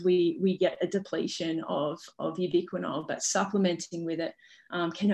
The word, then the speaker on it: ubiquinol: So tell me a little bit more about the ubiquinol, or how did you pronounce it? – Ubiquinol. – Yeah, ubiquinol, that's it.